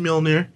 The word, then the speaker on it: Mjolnir: Thor lost, was it Mjolnir? I don't know how to pronounce it. – He lost Mjolnir. You said it right, actually.